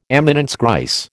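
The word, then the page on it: éminence grise: American English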